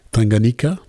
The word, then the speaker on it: Tanganyika: Do I say that right? – Tanganyika. – Tanganyika and Tanzania. – That is correct.